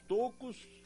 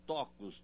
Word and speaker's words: tocos: Como você diz, havia muitos tocos ou muitos tocos de imbuia? As duas pronúncias são aceitáveis. O vocabulário ortográfico da língua portuguesa, em sua última edição, registra ambas as formas.